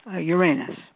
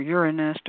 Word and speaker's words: Uranus: So Aquarius is nowadays said to be ruled by Uranus or Urania or Uranus, depending on how you want to pronounce it.